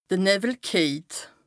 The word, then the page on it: de Nëwwel kejt: Bas-Rhin; Strasbourg